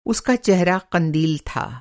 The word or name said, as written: कंदिल